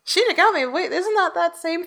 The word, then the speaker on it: Shinigami: I like how you're saying Shinigami because that's the right way to say it, but they had to call it Soul Reapers in English because Death Note was coming out at the exact same time.